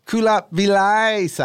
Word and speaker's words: Kulap Vilaysack: Hey, I actually have a problem with the way you mispronounced Kulap's name. – No, he said it right. He pronounced it correctly. – Kulap Vilaysa.